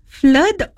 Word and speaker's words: flood: Flood or flood? Okay, it sounds like blood. Okay, yep. So you say flood.